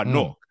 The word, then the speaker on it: Newark: I'm by Newark. I said it right this time.